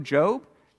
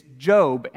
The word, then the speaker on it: Job: It's spelled like Job. But it's not Job, it's a name. It's Job.